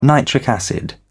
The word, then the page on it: nitric acid: British English